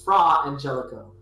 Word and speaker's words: Fra Angelico: Frangelico? – Yes. Actually, yes. But you're not pronouncing it right. That friar, the modern translation would be Fra Angelico.